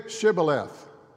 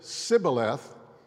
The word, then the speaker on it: Shibboleth: They would say, say Shibboleth. And if they said Sibboleth, because they could not pronounce the SH, they killed them.